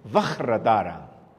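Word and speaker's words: Vajradhara: Tú que diste la pronunciación es Vajratara. Me acuerdo, eso fue una noche de desvelo en la zona himalayica con los lamas eh, regañándome cómo lo tenía que pronunciar correctamente.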